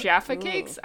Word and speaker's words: Jaffa cakes: Jaffa cakes? I don't know. How do you say it? – Yes. Jaffa cakes. Jaffa cakes. – Whatever. Rhymes with Jabba the Hutt.